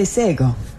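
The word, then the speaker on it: Shé:kon: When I first opened the show, you heard me say Sego. That is a Gonyageha word for hello, but it's not spelled like it sounds. It's spelled SHE:KON. The letter K sounds like a G.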